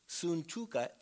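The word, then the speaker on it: Syntyche: He says, I urge Euodia, and I urge Suntuke, that would be the way it would be pronounced.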